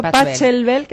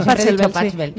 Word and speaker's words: Pachelbel: A ver si alguien me sabe decir alguna obra de Pachelbel, escrito Pachelbel, que muchas veces lo vemos escrito Pachelbel. – Yo hasta que aprendí a decir Pachelbel era como, ah, esto se dice así.